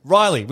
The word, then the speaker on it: Rhylee: I don't know, Riley, but it's spelled weird. – It's got a Y and a H. – Lists. – I mean, his name's Scott, and he's like, "Oh, I'm just going to name my kid some letters." – Yeah, it's like, isn't it R-H-Y-L-E-E? – Yeah. – Jesus. – Yeah. Riley, which is such an easy fucking name. They're like, no, spell it wrong.